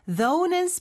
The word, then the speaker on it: though: though는 스펠링이 조금 독특해요. – Right, it's THOUGH. – 마지막 GH는 발음을 여기서 하지 않죠, 그쵸? though. – O-U-G-H, 이상합니다.